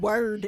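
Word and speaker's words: Wyrd: And I'm sorry, but no one who looks at the word word is going to think it's pronounced weird unless they pronounce weird wordly.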